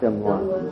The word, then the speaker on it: film noir: The film, how's that pronounced? Film, whatever, that style. – Film noir. – Film noir, right.